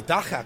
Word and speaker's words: Dagga: I am here with the Dagga couple. You said it correctly.